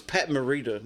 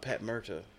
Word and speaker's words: Morita: And I know I said, it's Pat Morita, not Pat Murta.